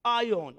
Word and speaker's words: aion: A-I-O-N. How do you say it? A-I-O-N. Ion? Ion.